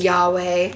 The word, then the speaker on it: Yahweh: believed in Yahweh.